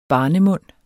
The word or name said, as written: barnemund